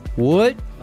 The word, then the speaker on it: Hold: Und dieses Hold auf Patois spricht man halt Hold aus. Das H ist stumm und das D hinten sprichst du auch nicht mit.